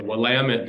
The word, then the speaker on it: Willamette: Yeah, so um, I, I learned this when I moved to Portland, but uh, I got corrected several times, but it's the Willamette, damn it.